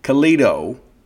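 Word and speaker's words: Calido: That's C-A-L-I-D-O. Kalido, right? Rhymes with Lido.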